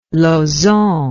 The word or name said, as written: Lauzon